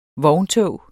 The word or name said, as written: vogntog